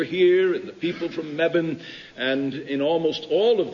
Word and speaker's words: Mebane: You pronounce that Mebane, by the way, and nothing causes a chuckle in a Mebaneite more than when people say, oh, you're from Mebane.